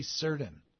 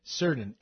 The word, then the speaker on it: Surdin: And if you take it to court, I had Ashley's name wrong. It's Ashley Cerdan. Ashley Cerdan with the Washington Post, Cerdan, S-U-R-D-I-N.